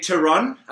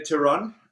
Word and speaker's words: Theron: It depends. I say Theron. In South Theron? Africa, we say Theron. Theron, there's nothing wrong with it.